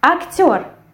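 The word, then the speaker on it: актёр: Актер. What can you notice here? – The same thing. We have the stress which falls on the last syllable. And we have this firm sound r.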